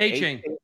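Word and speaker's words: Achane: I can't even say his name ever right. – A-Chain. – Thank you. All right. I always say A-Chain like that for some reason.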